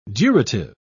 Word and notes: durative: British English

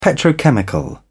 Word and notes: petrochemical: British English

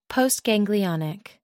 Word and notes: postganglionic: American English

